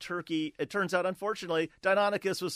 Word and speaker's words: Deinonychus: My favorite dinosaur as a kid, Marty writes, was, I'm going to mispronounce this, I think, was Deinonychus? – Deinonychus, yes.